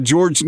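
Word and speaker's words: Georges: Okay, how about George Niang? How about Silent S or Silent Night? Because you don't, the S isn't pronounced.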